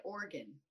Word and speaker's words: Oregon: How do you say the state in which we are talking about? – I've always said Oregon. – Of course you have, because that is correct.